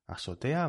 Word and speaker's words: azotea: Azotea va con Z, con Z. Azotea. Lo que pasa es que los nativos que seseamos, es decir, que no pronunciamos la Z, pues pronunciamos esta palabra así, azotea, ¿vale?